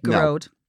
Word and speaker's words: Gearoid: Dear Geroid, am I saying your name right? – Geroid. – Geroid.